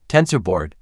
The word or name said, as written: TensorBoard